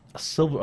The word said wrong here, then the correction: chivalrous: Oh, sorry, how do you say it? Chivalrous, right? Is mm-hmm. that correct? – Yeah, sure.